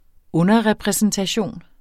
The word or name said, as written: underrepræsentation